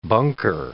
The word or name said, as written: bunker